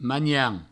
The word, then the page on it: manhan: magnan